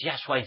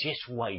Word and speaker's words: Yeshua: Y no es Yahshua, es Yeshua. Y vosotros escribís Yeshua, pronunciáis Yeshua.